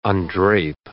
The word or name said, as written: undrape